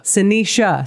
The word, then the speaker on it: Sinisa: Sinisa, is that right? – Sinisha. – Sinisha, see? – That's right.